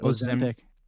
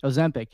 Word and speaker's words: Ozempic: Ozempic. Ozempic, yeah, yeah.